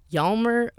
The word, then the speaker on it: Hjalmar: One Norwegian in particular stood out Hjalmar Hvam. Both his first and last names begin with a silent H.